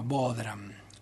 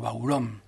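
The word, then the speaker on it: bodhrán: Y por último tenemos a Silvio Volpe, que toca la percusión, o lo que se llama bodhram, muy mal pronunciado, se pronuncia algo así como baurom, que es un instrumento de percusión irlandesa.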